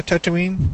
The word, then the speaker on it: Tatooine: Because I think they're also building like a Tatooine. Am I saying that right? – Yeah, Tatooine.